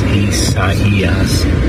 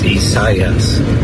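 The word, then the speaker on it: Isaías: No one says Esaias. There's no double accent in there. It's Esaias. Like gracias, you don't go Asias. You don't say gracias. You say gracias. Say Esaias. It's easy when you think of gracias, Esaias.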